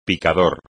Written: picador

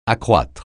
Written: accroître

